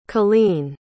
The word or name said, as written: cailean